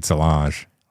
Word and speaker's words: Solange: I'm trying to meet Solange. Let's be honest here. – Okay, I like how you say her name. You say very French. – Solange.